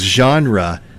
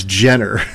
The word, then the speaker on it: genre: This person says, my mother pronounces genre as Jenner.